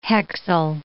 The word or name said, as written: hexyl